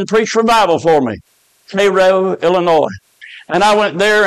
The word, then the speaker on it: Cairo: It's spelled like Cairo, but they call it Cairo.